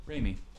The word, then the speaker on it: Raimi: Raimi.